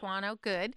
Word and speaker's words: Vantuano: Vantuano, good.